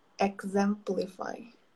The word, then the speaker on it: exemplify: Exemplify. Exemplify. E, e, exemplify. exemplify. E parece que é um G.